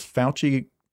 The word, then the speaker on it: Fauci: So today, I guess, Dr. Is Fauci correct? Am I saying his name right? – You nailed it. – Okay. – That's how you say it? – What do you say? Fossey? – Fauci.